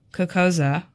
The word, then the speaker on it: Cocosa: So if you're like, how do I actually say Cocosa? You say it like that, by the way.